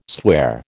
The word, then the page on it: swear: American English